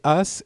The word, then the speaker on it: Haas: c'est As et Haas, ça s'écrit H-A-A-S.